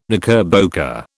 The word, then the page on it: knickerbocker: British English